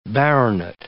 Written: baronet

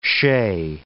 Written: chez

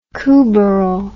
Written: courbaril

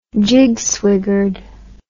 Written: jigswiggered

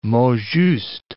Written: mot juste

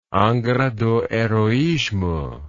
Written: Angra do Heroismo

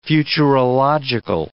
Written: futurological

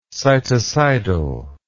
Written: cytocidal

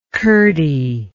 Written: curdiness

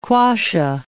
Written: quassia

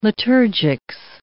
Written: liturgics

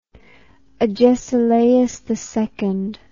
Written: agesilausii

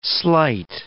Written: sleight

